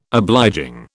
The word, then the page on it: obliging: British English